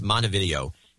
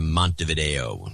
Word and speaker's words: Montevideo: It's pronounced Montevideo in Minnesota instead of Montevideo, as it would be in South America.